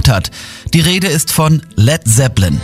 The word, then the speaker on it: Led Zeppelin: Led Zeppelin. Das A in Led ließ er einfach weg, damit es nicht wie Blei ausgesprochen wird.